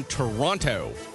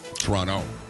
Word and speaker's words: Toronto: Is there not a T in Toronto? – There is. – Oh, but it's like saying Calgary.